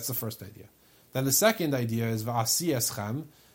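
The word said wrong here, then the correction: va'asa eschem (ועשה אתכם): ze yom shebo Yisrael laramses. Sorry, va'asa eschem. I mispronounced it.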